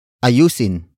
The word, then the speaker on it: ayusin: Word for word, it's ayusin mo ang sira ng ref.